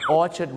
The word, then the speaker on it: Orchard: ราก็เป็นชาติเดียวที่ออกเสียงว่านาธานครับชาวโลกก็ออกเสียงว่า Nathan Road นั่นเองนะครับซึ่งชื่อฝรั่งนะครับเดี๋ยวถ้าผมมีโอกาสครั้งหน้าเราจะดูนะครับว่าชื่อฝรั่งนะครับหลายๆชื่อที่เราออกเสียงผิดมีอะไรกันบ้างนั้นฝากไว้หนึ่งชื่อครับ Nathan นะครับไม่ใช่นาธานครับแล้วก็อย่าลืมนะครับ Orchard Road ไม่ใช่ Orchard นะครับเพราะว่า ARD ต้องเป็นสระเออครับตัวอย่าง